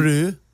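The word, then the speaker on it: Brü: Brew. B-R-U with one of those. What are those things above the U? – Umlaut. – It's called yeah. Umlaut, yeah. Brew. – Umlaut. – Pronounced Brew.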